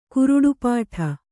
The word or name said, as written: ಗರುಡಪೀಠ